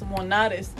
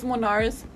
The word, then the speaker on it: Monarez: I always tell my mom siempre dice, tienes que pronunciar tu apellido como es Monarez. – Yeah. – But I told her, but I me gusta cómo suena en inglés. – What is it? – Yeah. – It's Monarez. – Monarez. – And I'm like, it sounds like more fancy, mom. He said, no, eres mexicana, tienes que pronunciarlo Monarez.